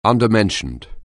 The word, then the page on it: undermentioned: anglais